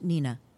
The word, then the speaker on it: Hnina: It was called H-N-I-N-A, pronounced Nina. H-N-I-N-A.